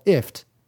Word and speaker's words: IFTTT: And actually, If This Then That is kind of pronounced like lift without the L. So it's called ift.